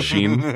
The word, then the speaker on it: Shin: Yeah, S-H-I-N, Sheen. Not Ultra Lord Sheen, but, you know, like Gain Sheen.